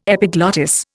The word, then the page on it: epiglottis: Indian